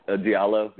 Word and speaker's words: Diallo: And I got it between Mitu from USC, Jontay Porter from Missouri, Grayson Allen, and then Diablo, I think, from Kentucky. I think I just butchered that. No, yeah, it's Diallo, yeah.